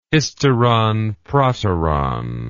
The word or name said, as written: hysteron proteron